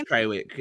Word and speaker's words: Trawick: Well, and now the new rumor coming out was that Jason Tarwick, is that how you pronounce his name? – Trawick, yeah.